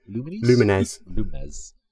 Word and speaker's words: Lumines: Lumines.